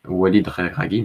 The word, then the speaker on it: Walid Regragui: Walid Regragui, merci Philippe.